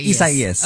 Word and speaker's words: Isaias: Isaias.